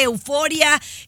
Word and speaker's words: Euforia: Gracias por escucharnos en la aplicación de Euforia, escucharnos en Spotify. – Eh, oh, pues, ¿qué dije? – Ah, euforia con acento en la U, Chiqui Baby.